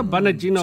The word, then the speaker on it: Bongino: How do you say it? – Bongino. – Yeah. – Hannity is Bonagino. – Yeah, Bonagino. – See, that's how you're supposed to say.